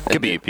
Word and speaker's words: Khabib: Khabib, yeah. – And then his last name is Nurmagomedov. His last name is for what? That's what it is. Conor McGregor was choked out by Khabib.